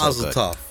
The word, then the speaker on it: Mazel tov: Did I say it right? I didn't say it right. – It's Mazel tov. – Mazel tov.